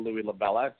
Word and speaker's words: Louie LaVella: And all of that is my first and last name, Louie LaVella.